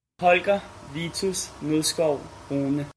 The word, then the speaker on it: Holger Vitus Nilskov Rune: Holger Vitus Nilskov Rune.